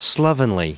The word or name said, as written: slovenly